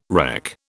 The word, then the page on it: reck: British English